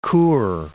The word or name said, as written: Chur